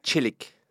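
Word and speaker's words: Çelik: meiega on Mustafa Selik, tere! – tere! – kas ma hääldasin su perekonnanime õigesti? – jah, enam-vähem, Tšelik. Tšelik? – jah okay., õige, see tähendab, et teras eesti keeles.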